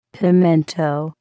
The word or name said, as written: pimento